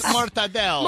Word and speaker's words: mortadella: Mortadella.